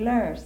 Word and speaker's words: loess: And Billy, you know LOESS? That's windblown soil that Vicksburg and Natchez, Billy pronounces that lurse.